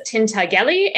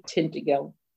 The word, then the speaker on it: Tintagel: So my inclination is in general to say Tintagel. It's just that then when I speak Latin, my training, which is in classical Latin, takes over because in classical Latin, a G is always a hard G.